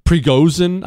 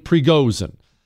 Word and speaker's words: Prigozhin: Prigozhin, I believe is how you say his last name. Prigozhin.